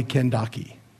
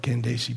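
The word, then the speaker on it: Kandake: As I looked at the Greek text, there's a, what we call a kappa, which is a K sound. It can be transliterated K or C, because in our language they're similar. But it's really probably Kendaki. But most people would call it Kendasi because of the C rather than the K.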